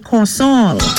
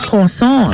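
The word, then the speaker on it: kòsòl: Your Creole word for today is the word Corsol. Corsol. It is spelled K-O-S-O-L, accent guave on both O's, a noun which means a sour salt.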